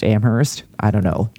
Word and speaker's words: Amherst: I mean, if he were that smart, he would know that it's not pronounced Amherst. We are recording this on my dad's birthday. He is a graduate. It's just Amherst. That's it.